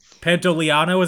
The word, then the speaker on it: Pantoliano: Uh, also, the the fifth starring actor in this movie, Joe Pantolano Pantoliano is actually how I pronounce it.